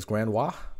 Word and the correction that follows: grandiose: How do you say that, Brandon? Grandiose.